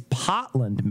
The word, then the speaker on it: Portland: It's not Portland, Maine. It's Potland, Maine. – What? – Yeah, they don't pronounce R's, so it's so Potland.